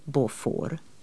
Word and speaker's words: Beaufort: Det uttalas Beaufort.